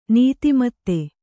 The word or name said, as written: ನೀತಿಮತ್ತೆ